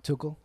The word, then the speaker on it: Tuchel: I saw this stat beat that highlighted Tuchel. That's how you say his name, right? Tuchel? – Tuchel. – Tuchel. – Yeah. I say Tuchel.